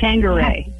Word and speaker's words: Tanqueray: Just like Tangeray.